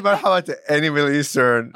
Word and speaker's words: Marhaba: M-A-R-H-A-B-A. Marhaba.